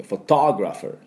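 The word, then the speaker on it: photographer: ここも半ボイン、最初のフォトのフォの、ところもちょっと軽くね、フォトじゃなくてフォトグラファーってことで、その後のトの方に力を、注いでください。そこにアクセントがありますので、でグラもあグラのアも半ボインで、ファーのア、ファーのところのアも半ボインです。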